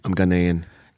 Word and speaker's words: Ghanaian: So, yeah, where you come from, how do you say it, Ghanaian? – I'm Ghanaian.